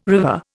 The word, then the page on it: river: Indian